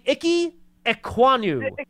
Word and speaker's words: Ikem Ekwonu: I thought he had can you help me pronounce the offensive tackle that you've Oh, yeah, that would be Ikem Ekwonu.